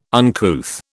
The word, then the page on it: uncouth: British English